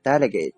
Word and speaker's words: delegate: OK，第二个单词呢叫做 delegate， delegate D-E-L-E-G-A-T-E delegate 这个词啊也有很多读音，比如说 delegate 也是可以的。接下来呢我们来看一个和 delegate 相关的例句。 Each delegate could be invoked separately。 OK， each delegate 就是说每一个代表的意思，可以被怎么样呢？